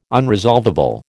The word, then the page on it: unresolvable: American English